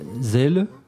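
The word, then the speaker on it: Celle: Il s'agit de Daniel Casimir, tromboniste, né à, tu me corriges si je prononce mal, Zell, en Allemagne, près de Hambourg, c'est ça? – Oui.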